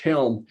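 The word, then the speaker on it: Chełm: It's spelled Chelm, but it's pronounced like a hard H, Chelm.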